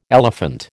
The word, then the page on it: elephant: American English